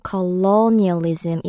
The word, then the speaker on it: colonialism: 이음절의 강세가 있어서요. colonialism 이로 발음할 때 조금 더 길게 발음하시면 돼요.